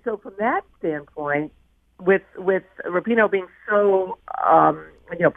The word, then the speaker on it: Rapinoe: And Megan Rapinoe, it's actually pronounced Rapinoe, talked, you know, I mean, she's fantastic.